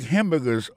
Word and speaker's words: hamburger: N'a pas remarqué que dans le mot hamburger, ça a comme tu as dit, il y a trois syllabes là-dedans. Quand tu appuies et puis ça, c'est sur le premier syllabe là.